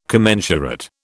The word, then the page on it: commensurate: British English